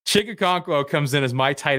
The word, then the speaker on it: Okonkwo: I'm not going to try to pronounce his entire Okonkwo. – Name. – Okonkwo.